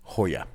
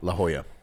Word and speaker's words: La Jolla: L-A space huh, as in J-O-L-L-A. – Hoya. – Oh. – La Jolla.